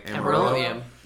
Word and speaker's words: Amarillo: In 1981 in Amarillo, Texas. That's how you say it, right? – Amarillo, Texas.